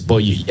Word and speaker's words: Boiuna: So yes, Beware Boyuna, that's spelled B-O-I-U-I-A. Yes, yeah. I got it. – Wow. – No, so yes, it's B-O-I-U-N-A. – Yeah. – B-O-I-U-N-A. There it is. Boyuna.